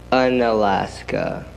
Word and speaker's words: Unalaska: Unalaska.